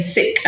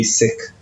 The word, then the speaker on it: Sikh: So you've been saying sick and I've been saying Sikh. And so... – Yeah, it's if a pronunciation... traditionally will be Sikh.